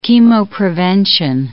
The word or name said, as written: chemoprevention